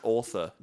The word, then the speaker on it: auteur: So Wes Anderson is like considered by film critics to be an auteur, mm-hmm. which means that his work is like really unique and immediately recognizable as his. – It's pronounced author, dude. – Sorry. Right, it is.